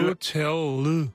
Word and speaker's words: Nutella: det skal ikke være et æh, det skal være et øh. Så den rigtige udtale af Nutella... – Nutella.